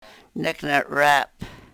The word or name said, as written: nek ne-rep